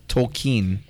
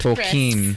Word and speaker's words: Tolkien: How do you say it? – Tolkien. – It even says it right there in the script. – Tolkien. – The common mispronunciation. – I like joke more. – It's Tolkien.